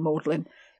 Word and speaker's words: Magdalen: Well, I think most people who haven't lived in Oxford or gone to Oxford might not know about Magdalen and Maudlin.